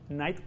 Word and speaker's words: Night: Chayamalan, no sé si lo estoy pronunciando bien, Night, así se llama el vato. Night como la noche.